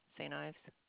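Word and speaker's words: St. Yves: Joanne, J-O-A-N-N-E, and then S-T space Y-V-E-S. – Is there an issue? – No. – And Saint, is that? – It's pronounced Ives, Saint Ives. – Saint Ives. – But it's a Y. – Okay. That's the last name. – Correct. So it's really S-T period Y-V-E-S, but on the credit card, there's no period.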